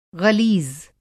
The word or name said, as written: ग़लीज़